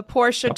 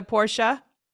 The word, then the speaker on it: Porsche: If you win tournament, you get a Porsche. Oh, sorry, Porsche. The proper auto term, right, is proper, Porsche. – Proper Deutsch pronunciation.